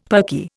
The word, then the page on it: perky: Indian